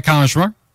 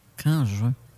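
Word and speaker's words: Canjuers: Le, plan qu'en juin. Ça se, passe, ça se prononce comme ça, qu'en juin, me semble.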